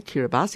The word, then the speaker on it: Kiribati: The other one is Kiribati. Or Kiribati, it's pronounced, sorry, Kiribati, even though it's spelled Kiribati, which is in Micronesia in the central West Pacific.